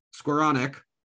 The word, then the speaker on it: Skowronek: to be able to keep coming back, and Van Jefferson, Skowronek, I believe is how you pronounce his name. Skowronek.